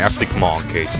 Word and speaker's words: Arctic Monkeys: Arctic Monkeys.